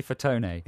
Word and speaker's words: Fatone: Mel B from the Spice Girls has got a new show with Joey Fat One.